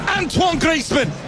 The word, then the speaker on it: Antoine Griezmann: That is number 300 across international and club games for that man, Antoine Griezmann. – Antoine Griezmann, lo pronuncian bien, la verdad.